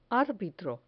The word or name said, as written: arbitro